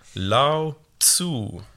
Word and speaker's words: Lao Tzu: Lao Tzu.